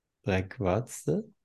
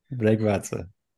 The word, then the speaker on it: Bregvadze: Herzlich willkommen und herzlich willkommen an unsere beiden Gäste, an äh, Nana Bregwatze. Ist das how you say it, Nana? – Bregwatze. – Bregwatze.